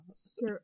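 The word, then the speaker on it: Seurat: How do you say his last name? Surratt? – Surat? – I don't speak French. – Surat. – I feel like it's Surat. Surat.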